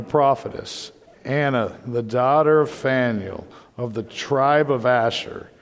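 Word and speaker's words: Phanuel: We're going to get to this guy's name, Anna's father, and the way it's pronounced, at least the way I'm going to pronounce it, is Faneuil. Because I like Faneuil Hall out in Boston, and when I saw it, I thought of that. So when we get to it, just say Faneuil. Faneuil, you're good to go, because it's just a weird P-H-A-N-E-U-L, I think is how it's spelled.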